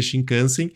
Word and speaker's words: Shinkansen: É a minha pronúncia de japonês, que é zero, mas eu vou falar aqui, é Shinkansen.